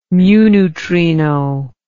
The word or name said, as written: mu neutrino